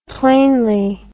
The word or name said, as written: plainly